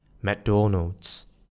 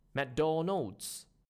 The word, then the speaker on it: McDonald's: Try to stress on the middle one instead. McDonald's versus the Mangish version. McDonald's.